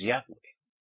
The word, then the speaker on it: Yahweh: Y pone mucho siempre YHWH, pero su nombre auténtico y verdadero es Yahweh. Esto téngalo bien en cuenta. Que aunque vean en las escrituras YHWH, es Yahweh.